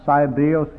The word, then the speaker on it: Cybele: The great goddess, Cybele in the Latin, which we pronounce it Cybele, C-Y-B-E-L-E, I suppose in English, the great goddess was the mother of gods and of men, and she was worshipped most of all in Rome.